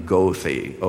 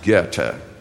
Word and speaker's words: Goethe: It was Goethe, and by the way, that German Enlightenment philosopher, don't pronounce his name Goethe, okay? He wouldn't recognize that. Learn a little bit of German, which will put you in some good stead when you get to heaven. And it's actually pronounced Goethe.